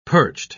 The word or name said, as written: perched